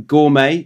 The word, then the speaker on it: gourmet: Next one, gourmet. Do you want me to just pronounce them? – Mm-hmm. – Gourmet. That's a good one. Mm-hmm. Silent T there.